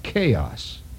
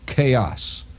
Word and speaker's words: chaos: While you're at it, look up chaos. It's not spelled with a K. It's spelled with a C-H, as in Charlie. C-H. Chaos. C-H-A-O. Chaos. With an S.